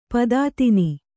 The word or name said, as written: ಪದಾತಿನಿ